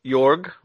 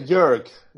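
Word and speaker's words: Jörg: Actually, it's we say Jurg. So Germans say Jurg, Michael.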